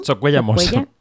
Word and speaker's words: Socuellamos: que es Berta Socuellanos, lo dije bien. – Socuellamos. – Socuellamos,